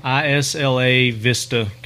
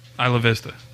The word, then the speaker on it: Isla Vista: ISLA Vista, California. – Isla Vista. – Isla Vista. Isla Vista, baby. – That's how we say it in Tennessee. – Yeah. Anyway, Isla Vista.